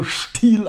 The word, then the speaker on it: Stil: Also man kann ja insofern, ist nicht ganz sicher, ob Stil oder Stil, aber wir sind in Deutschland und da sagt man, und der ST wird also Stil ausgesprochen.